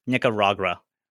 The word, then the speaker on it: Nicaragua: So I went to Nicaragua. I think I'm pronouncing it wrong. – Sounds wrong. – Nicaragua? – Yes. – Nicaragua.